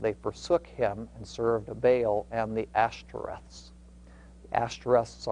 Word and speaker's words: Baal: I, I understand that the, the more technical pr pronunciation, probably more accurate, is Baal, okay? But uh, the more common use pronunciation is Baal, and that's even what the dictionary has as the pronunciation.